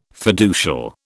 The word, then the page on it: fiducial: British English